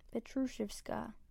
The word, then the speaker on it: Petrushevskaya: Petrushevskaya. – If you see how this name is spelled, it does not look like it would be pronounced like that. It's Petrushevskaya.